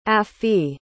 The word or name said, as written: affie